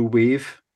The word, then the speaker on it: Wav: So yeah, so this is from their album that came out on Friday called Blue Wave. So it's pronounced wave, but it's spelled W-A-V, as in like a WAV file, I guess.